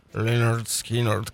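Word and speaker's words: Lynyrd Skynyrd: И сегодня мы пакуем свои чемоданы и отправляемся прямиком в Джексонвилл, штат Флорида, дабы обозреть замечательную группу под названием Линард Скиннерт, ну или Линард Скиннерт, как ее положено произносить.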